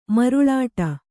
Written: ಮರುಳಾಟ

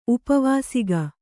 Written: ಉಪವಾಸಿಗ

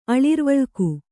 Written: ಅಳಿರ್ವಳ್ಕು

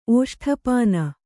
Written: ಓಷ್ಠಪಾನ

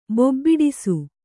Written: ಬೊಬ್ಬಿಡಿಸು

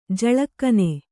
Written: ಜಳಕನೆ

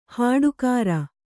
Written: ಹಟ್ಟಿಕಾರ

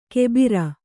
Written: ಕೆಬಿರ

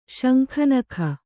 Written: ಶಂಖನಖ

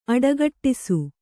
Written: ಅಡಗಟ್ಟಿಸು

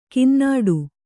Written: ಕನ್ನುಡಿ

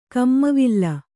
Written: ಕಮ್ಮವಿಲ್ಲ

